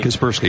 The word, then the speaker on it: Kaspersky: Uh, uh, is it pronounced Kaspersky? You got it right. Kaspersky.